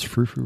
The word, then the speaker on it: frou-frou: Guys, did I pronounce frou-frou right? – Oh, absolutely.